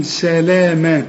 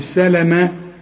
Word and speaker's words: سلاما: بعد اللام السلامة دي ألف عشان تقول سلاما مش سلامة، هي مكتوب سلامة أيوة صح كده، إنما كتابة القرآن خلافة النطق بتاعه، علشان كده بنقول لك افتح المصحف.